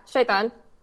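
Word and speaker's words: Shaytan (شيطان): So, yeah, Islam does identify Iblis with, correct me if I'm getting this pronunciation wrong, al-Shaytan? – Yeah, Shaytan.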